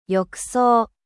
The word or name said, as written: baignoire